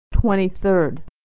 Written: 23rd

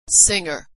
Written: singer

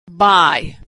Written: buy

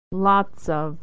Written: lots of

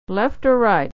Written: or right

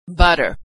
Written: butter

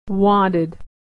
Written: wanted

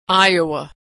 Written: Iowa

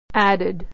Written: added

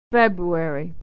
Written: february

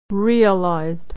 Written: realized

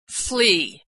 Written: flee

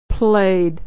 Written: played

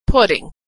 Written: putting

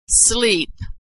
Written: sleep

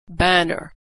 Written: banner

